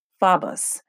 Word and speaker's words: phobos: When you look in Strong's Concordance, the number is G, for Greek, 5401, and it's spelled P-H-O-B-A-S, pronounced phobos.